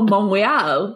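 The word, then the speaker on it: Montréal: Oh, Montreal. He like right, says correct, it correct, like correct. The French pronunciation.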